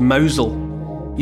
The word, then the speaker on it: Mousehole: That's spelt, if you're not familiar with it, it's as in mouse hole, M-O-U-S-E-H-O-L-E, but it's pronounced Mousel, you know, like M-O-W-Z-E-L in that kind of Cornish way. Mouse hole becomes Mousel.